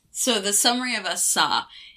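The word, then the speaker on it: Асса: That's Asa, that's right. I was pronouncing it wrong in the intro, but never mind.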